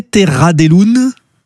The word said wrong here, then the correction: Terra des Lunes: Terra des lunes. – Des lunes, oui, voilà, oui, c'est un occitan.